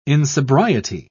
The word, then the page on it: insobriety: British English